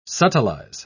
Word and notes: subtilize: British English

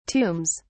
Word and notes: tombs: British English

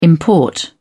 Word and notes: import: British English

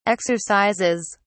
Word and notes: exercises: British English

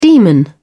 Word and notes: demon: British English

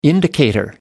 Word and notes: indicator: American English

